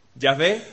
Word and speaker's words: Yahvé: Yahvé es la manera más aproximada como los israelitas, como los hebreos, deberían pronunciar esas palabras, que sabéis que no tenían vocales. Era una J, que se pronuncia Y, una H, una W y otra H. La W se pronuncia V,